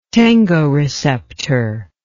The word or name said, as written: tangoreceptor